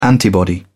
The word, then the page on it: antibody: British English